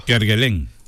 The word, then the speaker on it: Kerguelen: Kerguelen. – Ah, en Kerguelen.